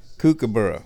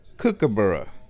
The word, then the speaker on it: kookaburra: And he informs me that in that particular flavor of English, the word kookaburra is pronounced more like kookaburra, where the first syllable cook is cook instead of kook.